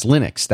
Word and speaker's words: Linux: They're the ones that say, now what's interesting is you get the creator of Linux, Linus Torvalds, says, I pronounce Linux as Linux. That's how he says it.